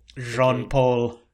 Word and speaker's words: Jean-Paul: But then they meet Beanpole. – Jean-Paul. – Jean-Paul. – Which is how they pronounce Jean-Paul.